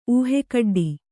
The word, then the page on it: ಊಹೆಕಡ್ಡಿ: ūhe kaḍḍi